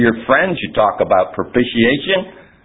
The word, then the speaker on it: propitiation: When I was a little boy, I was taught to pronounce it propitiation.